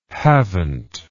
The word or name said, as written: havent